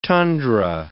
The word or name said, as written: tundra